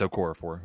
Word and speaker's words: Okorafor: Steelers offensive lineman Chukwuma, I don't even know how to pronounce his name, Okorafor. It's Okorafor who I'm talking about.